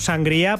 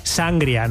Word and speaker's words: sangría: Bueno, vosotros decís sangria, ¿no? Lo pronunciáis mal. – Sangría. – Decís sangria. – Sangría. – No, pero es que le ponéis ahí el acento en la A, en la primera A.